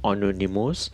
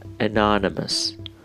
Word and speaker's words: anonymous: اساسا تلفظ آنونیموس یا تلفظ غیر انگلیسی است و از نظر انگلیسی به هیچ عنوان درست نیستش تلفظ درست این واژه به این شکل هستش anonymous anonymous anonymous یا anonymous برای بار آخر anonymous